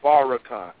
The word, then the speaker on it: Farrakhan: Y'all know we with him, so we got to say his name right. It's Farrakhan.